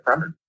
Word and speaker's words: Fremen: In our previous podcast, we had debated the pronunciation and we had proposed each of us our own pronunciation. I think Sean, you were the one who said Fremen.